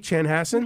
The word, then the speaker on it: Chanhassen: And did I pronounce the town's name right, Chanhassen? – You sure did.